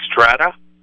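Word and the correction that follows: Strattera: Stratera, yes.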